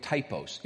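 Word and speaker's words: typos (τύπος): It's the word, it's spelled this way, you'll recognize it, T-Y-P-O-S. You see it? It's in the Greek, it sounds this way, typos. But it is the word, you would look at it just and look at typos.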